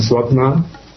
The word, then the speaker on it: свапна: Сон со сновидениями называется свапна, с ударением на последний слог.